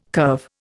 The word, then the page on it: curve: Indian